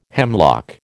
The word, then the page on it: hemlock: American English